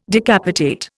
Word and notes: decapitate: Indian

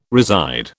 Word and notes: reside: British English

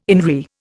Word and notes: INRI: Indian

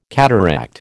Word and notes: cataract: American English

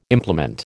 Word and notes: implement: American English

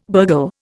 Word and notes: burgle: Indian

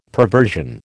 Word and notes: perversion: American English

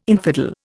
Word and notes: infidel: Indian

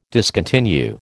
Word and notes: discontinue: American English

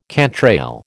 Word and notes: cantrail: American English

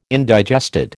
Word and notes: indigested: American English

